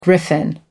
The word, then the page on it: griffin: American English